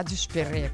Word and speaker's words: дюшпере: И почему именно ударение на «е» в конце слова, да, «дюшпере», кто знает, да, красиво.